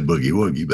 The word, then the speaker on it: boogie-woogie: He was trying to say boogie-woogie, but he kept using the soft G, and it is really funny.